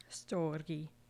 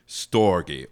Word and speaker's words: Storge: Storge. – Storge, or affection.